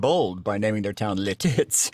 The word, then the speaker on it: Lititz: Yeah, Lititz. – Lititz, Pennsylvania. – L-I-T-I-T-Z. – That's right. I was pronouncing, I had the accent on the second half of the name, which didn't come out quite as well.